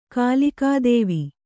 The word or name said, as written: ಕಾಲಿಕಾದೇವಿ